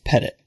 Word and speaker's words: Pettitt: And also, not to get us off on the wrong track, my last name is actually pronounced Pettit. So like you do with a cat or dog.